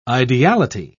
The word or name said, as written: ideality